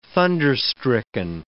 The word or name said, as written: thunderstricken